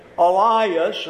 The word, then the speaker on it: Elias: I want you to notice that he says, Elias, and that's the Greek pronunciation of the name Elijah.